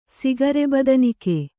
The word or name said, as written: ಸಿಗರೆಬದನಿಕೆ